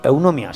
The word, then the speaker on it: Eunomia: Eunomia, sí, lo he dicho bien.